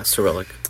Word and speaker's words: Cyrillic: Cyrillic? – Yeah, Cyrillic.